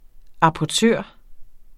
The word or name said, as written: apportør